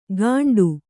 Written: ಕಂಡು

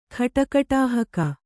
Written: ಖಟಕಟಾಹಕ

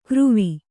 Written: ಕಾರವಿ

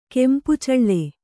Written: ಕೆಂಪುಚಳ್ಳೆ

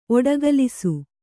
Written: ಒಡಗಲಸು

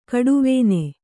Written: ಕಡುವೇನೆ

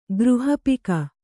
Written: ಗೃಹಪಿಕ

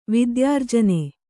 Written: ವಿದ್ಯಾರ್ಜನೆ

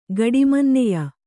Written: ಗಡಿಮನ್ನೆಯ

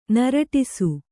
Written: ನೀರೂಡಿಸು

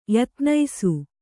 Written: ಯತ್ನೈಸು

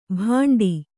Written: ಬಂಡಿ

